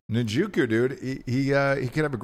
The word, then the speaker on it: Njoku: I'm going to take Njoku, however the fuck you say his name. Njoku.